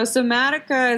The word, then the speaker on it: Somatica: Somatica and Haikomi methods? Yeah, it was almost perfect. Somatica was perfect.